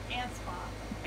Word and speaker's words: Anspaugh: How do you say your last name? I don't want to butcher it. – Anspaugh. – Anspaugh. – Anspaugh. – Pa. sorry. Anspaugh, like a.